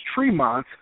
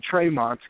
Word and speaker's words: Tremont: So there is some, I guess there is some history behind the last name, but I guess over here, how it's pronounced in my family and how it should be properly pronounced is Tremont, but more times less now, a lot of times I'll go to shows and the ring announcers will pronounce it Tremont, but either or, that doesn't bother me at all, you know, however they want to pronounce it.